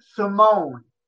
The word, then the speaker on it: S1m0ne: I'm drawing a blank on his name, but Simone is spelled S1M0NE1 and 0 as in digital.